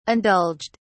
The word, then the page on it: indulged: British English